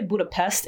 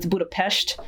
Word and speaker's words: Budapest: People say Budapest. It's Budapest. I learned that personally. Apparently, the S's are pronounced like shh.